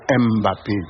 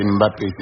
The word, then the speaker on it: Mbappé: Ce n'est pas Mbappé, non. C'est Mbappé, c'est une syllabe.